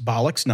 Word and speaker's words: bollocks: I do believe it's bollocks, not bullocks, but we'll go with it.